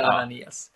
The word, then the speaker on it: Ananias: Eller är det Ananias? Jag vet inte hur man uttalar det. Ananias skulle jag väl säga. – Ja, vi säger Ananias.